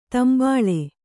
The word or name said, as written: ತಂಬಳಿ